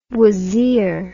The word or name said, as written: wazir